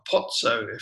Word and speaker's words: Pozzo: And it was a guy called Vittorio Pozzo, or Pozzo, if you're pronouncing it right in Italian.